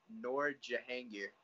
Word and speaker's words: Noor Jahangir: So from Fresh Meat 2, we have Noor Jahangir.